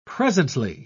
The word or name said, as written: presently